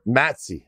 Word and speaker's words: MATC: Gadson caught Schachter's attention that day at the, how do you say it, M-A-T-C? Is there Matsy. – A short? – Matsy. – Okay, at the Matsy.